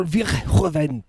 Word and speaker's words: Verhoeven: Paul Verhoeven. That's how you say his name. You gotta say it right.